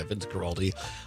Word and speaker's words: Guaraldi: And Vince Guaraldi, is that how you pronounce it, right? – I believe so, yeah. – Yeah. A fantastic playing of the piano by Vince Guaraldi, A Charlie Brown Christmas.